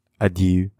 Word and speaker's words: adieu: Adieu.